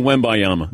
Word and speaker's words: Wembanyama: It's still Wembanyama.